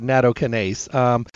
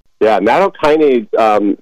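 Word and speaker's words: nattokinase: Natokinase, really interesting story. So again, you hear that A-S-E sound at the end, the natokinase. So that tells you it's an enzyme.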